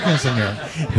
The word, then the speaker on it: Quinceañera: Quinceañera. – Yeah, yeah. We had a. I was having my quinceañera. – Oh, yeah. It was your quinceañera.